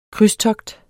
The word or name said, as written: krydstogt